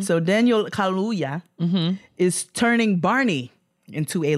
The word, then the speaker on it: Kaluuya: Daniel Kaluuya, I know that is not how you say his name. – Oh, okay. Yes. – Kaluuya? – Yep.